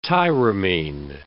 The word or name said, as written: tyramine